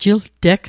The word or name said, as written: jilteg